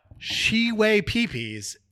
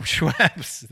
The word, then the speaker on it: Schweppes: I mean, listen, we've been there before, but Shiwei Peepees is so far from Schweppes that I mean, this is inside job.